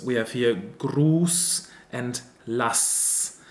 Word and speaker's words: lass: But here it is written with an SZ too, just like in Lass, which is also a short A sound. Lass.